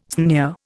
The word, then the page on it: sneer: Indian